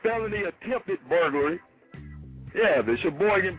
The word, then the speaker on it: Sheboygan: Sheboygan. It's the only right way to say it. Sheboygan.